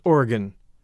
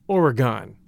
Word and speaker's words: Oregon: One suggestion, we pronounce my state of Oregon as Oregon, not Oregon.